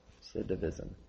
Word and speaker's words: recidivism: Recidivism.